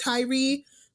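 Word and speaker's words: Tyre: but the I believe his name is pronounced Tyree.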